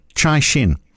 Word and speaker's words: Caixin: Caixin.